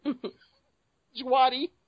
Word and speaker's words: Djawadi: Once again, Djawadi, or how you pronounce his last name, sorry if I pronounced it wrong. – D is silent. – The D is silent. Djawadi.